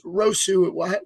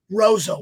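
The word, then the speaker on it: Roseau: You played for, I'm going to pronounce it wrong. I always said it was Rosu. What school did you go to? – Rozo.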